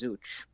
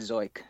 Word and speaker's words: Zeuch: I thought it's spelled Z-E-U-C-H, so I thought it was Zooch, but mm-hmm. I was listening to an interview, and they said Zoik, so Zoik is a better name, though.